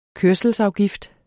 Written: kørselsafgift

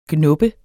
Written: GNUBBES

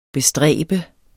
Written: bestræbe

